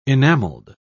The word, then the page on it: enamelled: British English